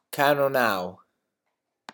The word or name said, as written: cannonau